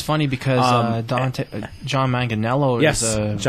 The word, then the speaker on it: Manganiello: Manganiello took a picture of himself with his shirt off, just completely jacked.